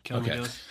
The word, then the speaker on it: Kelly McGillis: I remember reading something about Top Gun that Kelly McGillis, did I say her name right? – Yeah, Kelly McGillis.